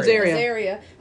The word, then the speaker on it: Azaria: Azaria.